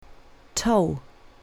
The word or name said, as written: toe